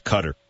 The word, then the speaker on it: Qatar: It's pronounced Cutter.